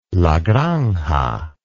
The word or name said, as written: La Granja